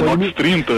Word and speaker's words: Nox 30: Nox III, Nox 3.0. – É Nox 30.